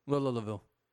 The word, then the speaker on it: Louisville: I like what he did at Louisville. – You said that wrong. Louisville. There you go.